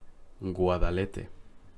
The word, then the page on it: Guadalete: Spanish